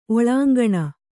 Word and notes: ಒಳಾಂಗಣ: oḷāŋgaṇa

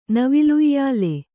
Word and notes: ನವಿಲುಯ್ಯಲು: kannada

